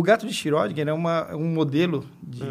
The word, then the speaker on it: Schrödinger: Como é que fala? Schrödinger? É Schrödinger. Schrödinger. Schrödinger.